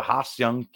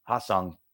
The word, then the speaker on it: Ha-Seong: All right, let's talk about Mr. Hassan Kim. Uh, I probably butchered that name, so let me actually try and get that pronunciation. So Ha-Seong Kim. All right, I didn't do so bad. Ha-Seong Kim.